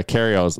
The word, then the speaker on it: Kyrgios: Kyrgios, or Kyrgios. The G is silent. Yeah, Kyrgios.